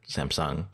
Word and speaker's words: Samsung: During Code Conference, Tim Cook said Samsung again, with the emphasis on Sung. So you're saying it wrong.